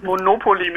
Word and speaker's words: Monopoly: Also die korrekte Aussprache ist Monopoly mit der Betonung auf dem zweiten O.